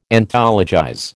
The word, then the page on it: Anthologize: American English